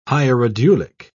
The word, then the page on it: hierodulic: British English